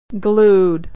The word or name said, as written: glued